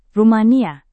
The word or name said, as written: rumania